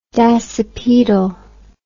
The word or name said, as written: dasypaedal